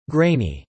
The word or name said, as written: grainy